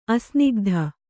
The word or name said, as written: ಅಸ್ನಿಗ್ಧ